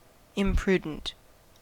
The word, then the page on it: imprudent: American English; /ɪmˈpɹudənt/